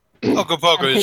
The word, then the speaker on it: Ogopogo: And I used Ogopogo. Did I say that right? – Ogopogo. You may have. It's not Hongo Bongo. I keep getting those confused. – I don't remember what its name is, but Ogopogo is correct.